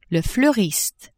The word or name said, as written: fleuriste